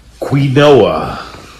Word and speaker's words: quinoa: Quinoa. You know that thing that you never know quite how to pronounce. Quinoa. Q-U-I-N-O-A.